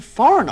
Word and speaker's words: foreign: In these sentences, don't pronounce the G in foreign. – The Prime Minister has given the Foreign Office to Mr. Skinner.